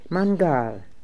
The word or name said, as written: Mangaal